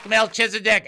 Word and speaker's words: Melchizedek: Well, I don't know if I'm going to say it right, but Melchizedek? – Close enough! You get it! Da-da-da-da-da! And yes, Melchizedek.